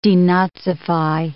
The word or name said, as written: denazify